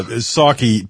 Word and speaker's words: Psaki: Yeah, Psaki, P is silent, just like President Biden.